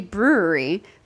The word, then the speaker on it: Bruery: So basically, there is a place called the brewery. But it is spelled B-R-U-E-R-Y. So it's a fancy way of spelling brewery.